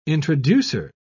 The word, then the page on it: introducer: British English